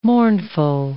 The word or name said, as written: mournful